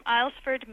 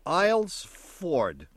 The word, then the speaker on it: Islesford: I'm calling from Islesford, Maine. – Hi. – Islesford. – Islesford. – I-L or I-S-L? – I-S-L. Silent S. – Islesford.